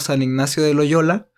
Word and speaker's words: San Ignacio de Loyola: Pero justo eso, venía platicando con Roberto, que había investigado este tema de las apuestas, y encontramos que el famosísimo San Ignacio de Loyola, ahora sí lo dije bien.